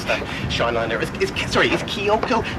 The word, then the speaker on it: Kyoko: Because you said Kayoko as if she's a coyote or something.